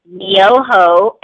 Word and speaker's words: Myoho: But it's Nam, which is N-A-M as in Mary, Myoho, M-Y-O-H-O, Renge, R-E-N-G-E, Kyo, K-Y-O.